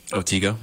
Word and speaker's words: Otego: And originally from Otego. Is that how you say it? – Otego, yeah.